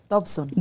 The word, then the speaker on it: Dobson: Mencionaste al doctor, no sé si lo voy a pronunciar bien, Dawson. Dobson. Dobson. Dobson.